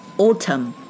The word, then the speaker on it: autumn: m 发 m 的音，但是 n 不发音。 autumn，秋天，秋天是落叶子的 fall，所以 fall，f a l l 也是秋天的含义。